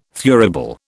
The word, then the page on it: thurible: British English